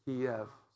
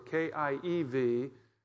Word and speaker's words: Kiev: By the way, the name Kiev, Pastor, is uh, that's how you say it in Russian, Kiev. So K I E V that's the way we grew up saying it, Kiev.